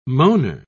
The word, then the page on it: moaner: British English